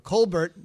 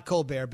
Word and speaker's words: Colbert: Colbert, not Colbert, but spelled like Steven, has been in the organization now for more than 20 years.